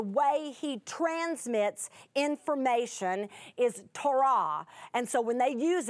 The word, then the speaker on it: Torah: It's Torah, Torah. It's the way we would say Torah is the way someone in Judaism might say it with the accent on the second syllable.